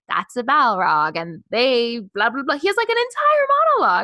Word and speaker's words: Balrog: Because then we meet the Balrog. Is that how you say it? – Balrog.